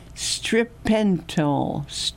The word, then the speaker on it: stiripentol: And the clinically approved drug used in the study, stiripentol. – Want me to pronounce it for you? – S-T-I-R-I-P-E-N-T-O-L.